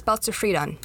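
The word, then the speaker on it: boustrophedon: Balstafridon. Did I say it right? – Close. No, it's Belzer-Friedan. Yeah.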